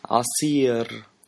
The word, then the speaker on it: عصير: Juice means عصير, عصير. I want juice, بدي عصير. Make sure to pronounce the R, it's a rolling R. And then the عين, it's like a throat letter.